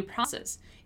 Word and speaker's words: process: Here, I want you to pay attention to the pronunciation of the word process, right? Process, process, process. The O is open.